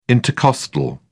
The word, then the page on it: intercostal: British English